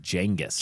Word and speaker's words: Genghis: I think it's pronounced Genghis.